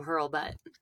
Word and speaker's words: Hurlbutt: So many people try to be polite and they'll say Herbert or like Hurlbutt because they feel like they shouldn't say it the way it's spelled. But that is the way it is.